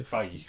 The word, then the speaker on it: Feige: And then he said it's pronounced... What did you say? – Faye. – Feige? – Faye. – Feige. – Kevin Feige. – Kevin Feige. – That's his name.